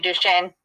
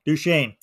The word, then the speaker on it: Duchesne: The 480-acre ranch is located about 3.5 miles southwest of Fort Duchesne, I think it's how it's pronounced, Utah, and borders the Ute Indian Reservation. – I think it's actually Duchesne. – Okay, we'll go with that. Duchesne.